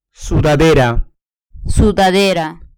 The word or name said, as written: sudadera